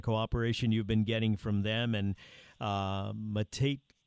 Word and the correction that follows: Matute: Cruz. I got his name right. – Cruz Matute. – Cruz Matute. – Yes.